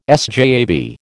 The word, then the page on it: SJAB: American English